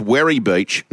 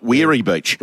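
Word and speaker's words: Werri Beach: Levy, can you please tell Piggy that it's pronounced Werry Beach, not Weary Beach? – Weary Beach.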